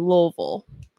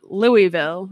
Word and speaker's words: Louisville: Yeah, he's in the bottom left of Kentucky in Louisville. It's not Louisville, it's Louisville. – It's Louisville, I'm sorry. – Mm-mm. It's all supposed to be like one one syllable.